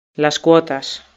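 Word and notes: las cuotas: Spanish (Spain)

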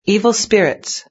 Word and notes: evil spirits: British English

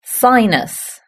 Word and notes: sinus: British English

